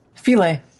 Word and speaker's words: Philae: Rosetta identifies five possible landing sites for, how do you pronounce it? – Philae. – Philae. Well, that's easy enough.